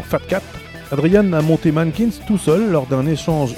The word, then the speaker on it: MNNQNS: Juste après, on écoutera MNNQNS, prononcé Mankins, groupe français. If Only the Cold, ce sera le morceau, rock français déjanté et chanté en anglais. Mankins, c'est un groupe français qui nous donne envie d'écouter du rock.